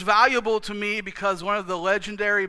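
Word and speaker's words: tome (τιμή): Time. It looks like time, but it's actually pronounced tome. Tome.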